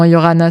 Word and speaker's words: Iorana: Donc, sur Insta, Facebook, Yorana, donc I-O-R-A-N-A, avec un I. Donc, le pseudo complet, c'est Yorana, I-O-R-A-N-A, tiré du bas avec, c'est tiré du bas, un I. Mais normalement, Yorana, ça suffit à me trouver, parce que avoir ce pseudo, déjà personne n'arrive à le prononcer.